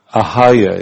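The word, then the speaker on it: Ahalya: Was actually Aloha. What's that? Ahaya, yeah, I pronounced it wrong, I thought.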